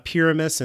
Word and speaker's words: Pyramus: Yeah, there's also an element in this story of an ancient story called Pyra- well, I'm going to say that maybe I'm going to say this wrong because I've only ever read it. Pyramus and Thisbe. – That's how I've always heard it.